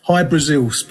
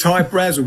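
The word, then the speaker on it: Hy-Brasil: High Brazil, people pronounce it High Brazil, and it's Brazil, okay. – Yeah, yeah. But it's High Brazel because that's how the Irish would pronounce it, because it's an, okay.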